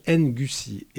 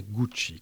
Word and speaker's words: Ngoussi: Alors, on dit, ça s'écrit Ngoussi. Et en fait, elle nous a dit l'autre jour que ça se prononçait Gucci, comme le couturier italien.